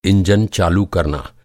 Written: इंजन

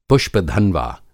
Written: पुष्प-धन्वा

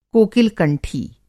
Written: कोकिल-कंठी

